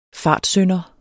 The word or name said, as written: fartsynder